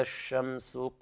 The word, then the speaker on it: الشمس: إذا الشمس كورت، كذلك الشين فيها التفشي، بين فيها التفشي، والسين كذلك فيها الهمس. إذا الشمس، وطبعا هنا اللام لام شمسية لا تظهر، تكتب ولا تنطق، فيها تضغم في الحرف الذي يليها.